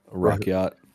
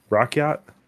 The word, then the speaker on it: Rakyat: So, yeah, and then you kind of work with the, how do you say it, Rayat? – Rakyat. – Rakyat.